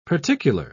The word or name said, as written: particular